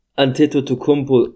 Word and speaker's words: Antetokounmpo: Griechenland, ähm, der absolute Superstar Janis Atatakumpo ist nicht mit dabei, hat abgesagt. Weißt du, ganz kurz, du kannst nicht Fentecchio aussprechen, aber du kannst mir hier Antetokounmpo aussprechen. Ja, weil ich das permanent höre.